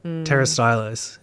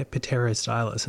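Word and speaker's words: Pterostylis: And then the silent P, that always gets me. – Yep. – Like pterostylus, and you look at it and it's like, oh yeah, pterostylus, and it's like, that is not.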